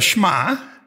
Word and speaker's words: Shema: It is called the Shema. I'm not mispronouncing that word, and I'm not creating that word. It is S-H-E-M-A.